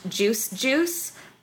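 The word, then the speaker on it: Juice=Juice: To me, I'm a fan of my current new idol that I've been following is a new member of the group, pronounced Juice Juice, but their spelling is Juice equals Juice.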